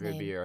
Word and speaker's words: Ribeiro: Yes, I was absolutely devastated to learn that Alfonso Ribeiro, I still don't know how to pronounce his Ribeiro.